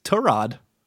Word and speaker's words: Tyrod: It's Tyrod.